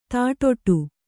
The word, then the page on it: ದಡ್ಡಿ: kannada